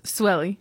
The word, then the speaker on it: Suely: Suely.